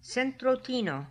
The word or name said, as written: centrotino